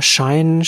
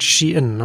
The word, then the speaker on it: Shein: Und fangen wir mit äh, Shine, Shein, Fashion an. Kann man unterschiedlich aussprechen wir, wir, wir, sagen, wir sagen Shine, aber eigentlich das Unternehmen sagt Shein.